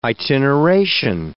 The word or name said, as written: itineration